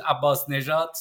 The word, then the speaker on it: Abbasnejad: It is Abbasnejad.